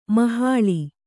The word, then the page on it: ಮೊಹಲ್ಲಾ: mohallā